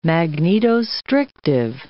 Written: magnetostrictive

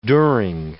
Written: during

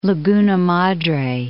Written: Madre, Laguna

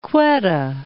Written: Quetta

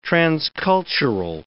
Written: transcultural

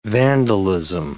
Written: vandalism

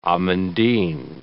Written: amandine

